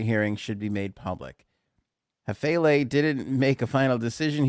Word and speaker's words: Hafele: H A F E L E Hafele.